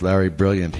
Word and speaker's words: Brilliant: And I'm not a doctor, but this doc that I worked on the movie with, Larry Brilliant, he was along, he says it rhymes with stupid, he came along because the Warner Brothers gave a lot of money to free clinics.